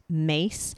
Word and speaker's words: Myss: Her last name is M-Y-S-S, but it's pronounced Mace.